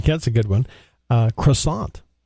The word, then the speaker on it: croissant: You'll hear it the French way where they get the back of their mouth, they go croissant like that, and then you'll get the English way where it's just croissant, you know. – I like the way you said that the first time. Say that again. – Croissant. That's how they say it in France. It's a French food.